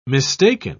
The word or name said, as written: mistaken